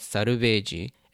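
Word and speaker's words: saruveji (サルベージ): And so when you say salvage in its Japanese sort of form, it's called saruveji.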